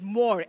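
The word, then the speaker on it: mor (מור): Myrrh, in the Hebrew language, right, is pronounced as mor, M-O-R, mor, and actually brings the meaning bitter.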